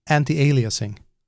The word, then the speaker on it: anti-aliasing: I think the main new feature that Katarina pointed out were anti, and now I will mess up the pronunciation, analyzing? – Yes, you did. Anti-aliasing.